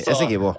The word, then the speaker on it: Essequibo: Men ok, så det da denne regionen som heter Essequibo, det sånn det uttales? – Essequibo, ja. Ja, jeg ville sagt Essequibo, men jeg faktisk ikke helt sikker på hvordan man uttaler det. Det kommer litt an på hvilken språkbakgrunn man har det. Tenker jeg spansk, så sier jeg Essequibo, altså. – Ja, riktig. Essequibo. Ja.